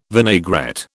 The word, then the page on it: vinaigrette: British English